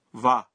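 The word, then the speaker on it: وا (は): میں حیران ہوں کہ جب ہم ہیرا گانا میں لفظ وا لکھتے ہیں تو ہم وا کے بجائے ہا کا حرف استعمال کرتے ہیں. تاہم اس کا تلفظ وا کرتے ہیں.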